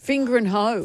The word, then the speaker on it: Fingringhoe: F-I-N-G-I-R. – Start again. – F-I-N-G-R-I-N-G-H-O-E. – Finger and hoe.